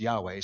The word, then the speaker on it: Yahweh: Well, the Lord and the Lord's name, his personal name, we think is pronounced Yahweh. So you'll hear me say Yahweh, you'll hear me say the Lord.